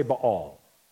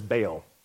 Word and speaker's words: Baal: Everybody say Baal. You might also hear him pronounced in English as Baal.